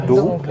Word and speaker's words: Dohuk: Du bist ja selber mit 9 Jahren aus Dohuk, richtig ausgesprochen? – Dohuk, ja.